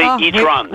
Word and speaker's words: e-tron: Il faut prononcer E-tron. – Oui. À l'anglais, c'est plus chic. – On prononce pas à la française, c'est pas très joli.